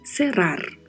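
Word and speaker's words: cerrar: Serar, que também é um verbo agora de primeira conjugação, que são os verbos terminados em ar, significa fechar, Quanto à pronúncia, precisamos ter cuidado porque sempre a letra E é mais fechada, né? O som de E, como já falamos várias vezes. E esta palavra terá o vibrato do R, tanto no meio da palavra quanto ao final da palavra.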